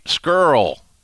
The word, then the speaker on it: Scurll: So anyway, Marty Scurll rhymes with girl.